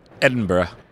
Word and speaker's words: Edinburgh: And we were crossing a road in Edinburgh, and I just want to check you can say the name right. – Edinburgh. – That's good. That's good. That's not bad.